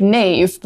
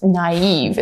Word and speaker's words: naive: It looks like knave, but it's naive. It's actually the only word in English where the I can have two dots over it because it is its own syllable, naive.